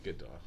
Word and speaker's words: Gadot: Gal Gadot, that's how you say it, right? – Gadot, yeah.